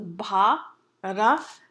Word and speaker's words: Bharata: Bharata comes from three syllables, Bharata.